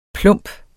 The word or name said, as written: plump